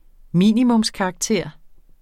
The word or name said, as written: minimumskarakter